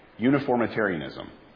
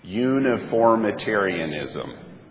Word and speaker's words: uniformitarianism: Uniformitarianism. All right, everybody say it with me. Uniformitarianism. It's 9 syllables.